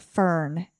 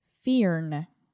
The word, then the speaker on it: firn: It looks like fern, F-I-R-N, but you actually pronounce it fearn, like the I-R says ear, so fearn.